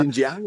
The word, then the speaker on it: Xinjiang: parlait par exemple des journalistes qui étaient suivis euh, visiblement quand ils se promenaient dans la région de, du, Xinjiang, je ne sais pas si je mhm. le prononce correctement. Xinjiang, oui.